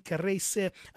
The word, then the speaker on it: Kareyce: It's her name is spelled K-A-R-E-Y-C-E, Karese Foto. Surname is F-O-T-S-O.